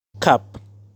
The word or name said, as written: Cup